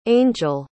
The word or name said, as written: angell